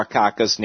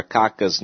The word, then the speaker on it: Akaka: It became very natural saying his name because of the three syllables, the first one soft and the two hard ones, said David J. Tinsley, a longtime legislative clerk who called Mr. Akaka's name hundreds of times before leaving the Senate in 2008.